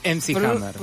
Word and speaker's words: MC Hammer: Mac Hammer. – Sí. Sí. MC Hammer.